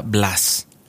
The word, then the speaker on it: Blaise: Pero yo recuerdo que cuando yo estaba en la escuela básica nos enseñaban que era Blas.